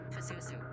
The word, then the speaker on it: Pazuzu: Pazuzu.